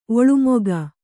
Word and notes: ಒಲ್ಮೆಗ: olmega

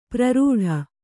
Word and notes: ಪ್ರರೂಢ: kannada

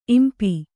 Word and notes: ಇಂಪಿ: impi

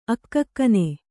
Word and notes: ಅಕ್ಕಕ್ಕನೆ: akkakkane

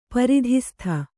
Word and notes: ಪರಿಧಿಸ್ಥ: pari dhistha

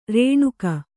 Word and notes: ಋಣಿಕೆ: řṇike

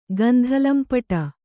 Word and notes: ಗಂಧಲಂಪಟೆ: Kannada

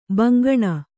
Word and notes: ಬೆಂಗಾಣ್: kannada

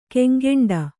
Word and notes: ಕೆಂಗೆಂಡ: kannada

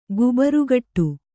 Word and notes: ಗುಬರುಗಿಡ: gubarugiḍa

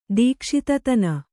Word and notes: ದೀಕ್ಷಿತತನ: dīkṣitatana

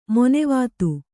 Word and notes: ಮಾನವತೆ: mānavate

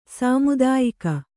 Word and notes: ಸಮುದ್ಯೋಗ: smudyōga

